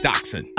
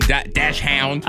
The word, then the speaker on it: dachshund: And just so you know, it's pronounced dachshund. – Uh-huh. – Don't be out here saying dash hound.